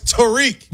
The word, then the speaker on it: Tarik: First of all, put some respect and say his name right. It's Tarik.